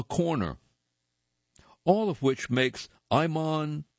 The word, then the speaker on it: Ayman: And before we begin, Iman O'Day. Iman, first name is spelled A-Y-M-A-N. Last name, O'Day, is spelled O-D-E-H.